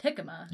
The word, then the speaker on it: jicama: I was pretty sure I saw one that was Jicama, which starts with a J, but it's not pronounced like it's pronounced Jicama.